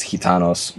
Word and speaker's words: Gitanos: He does a great job on the flag, and it's, you know, Gitanos, I mean, if you're doing it right, you pronounce the G like an H, and it's Gitanos.